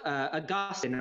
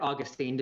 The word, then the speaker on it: Augustine: Augustine, or Augustine, depending on how you want to pronounce his name, he has a short work called On Christian Doctrine.